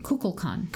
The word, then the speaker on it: Kukulkan: But how do you say it? Kukulkan? – Kukulkan. – Kukulkan. – Like a cuckoo clock.